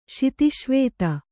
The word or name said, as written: ಶಿತಿಶ್ವೇತ